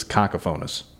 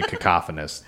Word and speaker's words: cacophonous: This is cacophonous. – What? What'd cacophonous. – You call me? – I think it's actually cacophonous. That's how you say it.